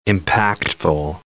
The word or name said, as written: impactful